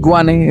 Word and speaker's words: Guane: Y Wane es una etnia indígena de aquí en mi región de Colombia. Se escribe G-U-A-N-E.